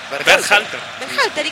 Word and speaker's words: Berhalter: Berhalter.